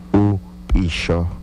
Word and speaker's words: upisho: O upisho ake, que sería despertarse en las noches. Excelente, querida familia. Muy bien, nuestra palabra fue despertarse, que en lengua yocotán se pronuncia upisho.